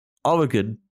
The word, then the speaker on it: Oregon: Oregon.